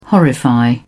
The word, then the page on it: horrify: British English